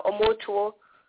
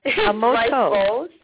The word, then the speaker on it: omoto: It was a very funny pronunciation, but it's omoto. Oh, sorry. Omoto. So you say it almost like it's one word.